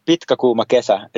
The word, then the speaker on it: Pitkä Kuuma Kesä: How do you pronounce this? Kuma Kesä? – Yeah, Pitkä Kuuma Kesä.